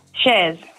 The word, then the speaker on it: Chers: Shers or Shers? – Shers. – Shers, because the girls here have written your name as C-H-E-R-S. Is that how you spell it? – That's it.